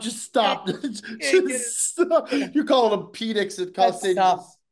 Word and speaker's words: Penix: Michael Penix Jr. Penix, yeah.